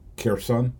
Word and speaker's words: Kherson: Um, and then in the recently liber- so-called liberated Kherson, or sometimes I'll pronounce it Kerson.